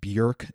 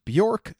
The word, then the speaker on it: Björk: First off, yes, her name is pronounced Björk, not Bjork, so you can say that properly now because I didn't for a very long time.